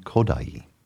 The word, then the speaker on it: Kodály: David Oistrakh, violin, and Vladimir Jampolsky, piano, three Hungarian folk songs arranged by Zoltán Kodály. Hungarian language, the accent's always on the first syllable, and that's often the case with music.